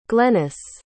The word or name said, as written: glennis